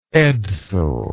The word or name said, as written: edsel